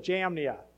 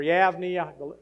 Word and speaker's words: Jamnia: Now, you might have heard or you might have read that the Old Testament canon was decided at the Council of Jamnia, or Yavnia, depending on how you want to pronounce it, but Jamnia by the coast is a city.